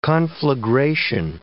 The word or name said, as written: conflagration